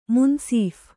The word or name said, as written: ಮುನಸೀಫ